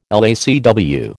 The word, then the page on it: LACW: American English